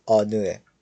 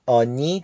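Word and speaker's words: آنو (어느): خب کلمه بعدی ما کلمه آنوه. آنو به معنی کدامه. دقت کنید که این کلمه آنی تلفظ نمیشه، بلکه آنو تلفظ میشه.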